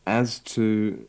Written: as to

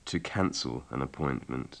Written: to cancel an appointment

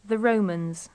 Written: the romans

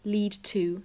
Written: lead to

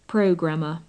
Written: programmer

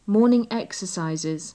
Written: morning exercises

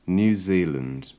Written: New Zealand

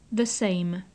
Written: the same

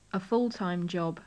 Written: a full time job

